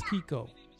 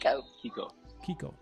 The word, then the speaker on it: Keko: My name is Kiko. – What was it? – Kiko. – Spell it. – K-E-K-O.